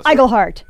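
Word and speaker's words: Iglehart: James Monroe Eigelhart. – Eigelhart. – Oh, so right. Close. Hard G. Okay. Eigelhart. And Utkash Ambudkar.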